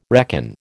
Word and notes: reckon: American English

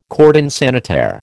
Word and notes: cordon sanitaire: American English

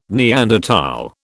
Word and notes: Neanderthal: British English

